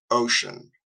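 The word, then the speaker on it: Wouxun: Myself, I have the Ocean brand, but it's Ouxun, but it's pronounced Ocean.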